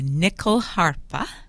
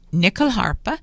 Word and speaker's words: nyckelharpa: The nyckelharpa. Nyckelharpa is the Swedish folk fiddle.